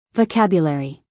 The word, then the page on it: vocabulary: American English; female